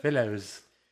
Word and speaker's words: Filo: Filos. – I just thought it was Filo. – Filo. – Like Milo. – Yeah, like the drink.